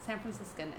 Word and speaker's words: San Franciscan: San Franciscan, and that's how you pronounce it.